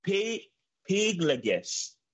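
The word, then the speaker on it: pilegesh: And the way to pronounce it is piglegesh.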